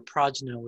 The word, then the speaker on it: prajna: Prajna is the better internal pronunciation, because you know, it can get confused with the other Indian version of prana, which is related to chi, the physical aspect of chi.